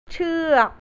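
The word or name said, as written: กระโดดเชือก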